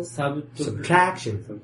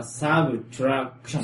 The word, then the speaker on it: subtraction: Usually when the word has three syllables, the stress is in the middle. Usually. Generally, I mean. – So, Subtraction. – So, three syllables is subtraction. – Subtraction. Subtraction. – Subtraction. Subtraction. – Subtraction. Addition. – Addition. – Subtraction. Sub is not stressed.